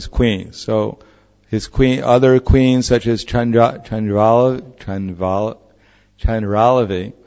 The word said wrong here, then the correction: Chandravali: how do you pronounce the name? Chandravali is easier.